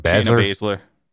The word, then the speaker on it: Shayna Baszler: Well, and Team Evil Bad Guys bringing up, I can't remember how to say her name. Bazler? – Shayna Baszler.